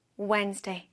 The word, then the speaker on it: Wednesday: Today is Wednesday. 여기 스펠링 잘 보면 D가 들어가 있죠? W-E-D. 그런데 이 D는 소리 내지 않는 글자여서 그냥 Wednesday라고 하면 됩니다. Today is Wednesday.